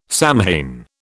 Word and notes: Samhain: British English

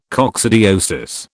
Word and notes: coccidiosis: British English

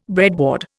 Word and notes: breadboard: Indian

